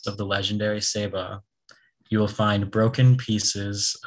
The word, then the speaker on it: Ceiba: The word Ceiba comes from a Taino word pronounced Ceiba.